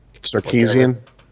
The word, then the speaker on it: Sarkisian: The fact that Kyle Shanahan left the Falcons, and then how do you pronounce his last name? – Sarkisian. – Oh, Sarkisian. – Sarkisian.